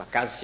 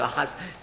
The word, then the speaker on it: Ahaz: C'est Ozias pour quelques mois qui est encore le roi, mais il va mourir, Jotham va lui succéder, et peu après, Ahaz va prendre le pouvoir, effectivement. Ahaz, ou Akaz si vous préférez, mais ça se prononce en hébreu Ahaz.